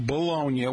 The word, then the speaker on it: Bologna: It's derived from the Italian city of Bologna, pronounced Bologna, which makes more sense, and the place was mortadella was invented.